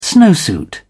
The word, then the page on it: snowsuit: British English